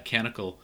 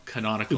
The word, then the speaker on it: Canonical: They're partnering with Canonical. – Canonical. – Canonical. – Oh, I said it wrong. Canonical.